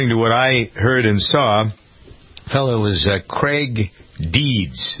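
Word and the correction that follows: Creigh: Is that how you pronounce it? Was it Craig or Cray? – Cray is his name.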